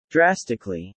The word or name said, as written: drastically